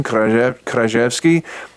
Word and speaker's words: Kraszewski: By the way, and, the, and he threw me a curveball because he pronounces his last name with the Polish pronunciation, Kraszewski, but he sort of Americanized Gracjan uh, to Gracian.